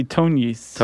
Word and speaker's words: Tognis: Do you know how to pronounce this one? T-O-G-N-I-S? – I would say Tonyis. – Tonyis, that's what I was going to say.